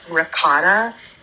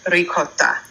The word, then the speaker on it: ricotta: I want to work for her now, but it is definitely not ricotta. It's ricotta, which Brandon is right. It's R-I-C-O-A-T-U-H, spelled phonetically, of course.